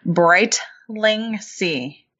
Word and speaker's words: Brightlingsea: Brightlingsea, just like it looks.